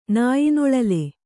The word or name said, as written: ನಾಯ್ನೊಳಲೆ